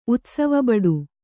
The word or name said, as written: ಉತ್ಸವಬಡು